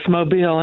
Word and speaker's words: Mobile: Yes, Mobile, and thank you for taking my call.